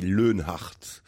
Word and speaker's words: Leonhardt: Et le mien, il est difficile à prononcer parce que ça se dit Leonhardt. Leonhardt, c'est très très germanique.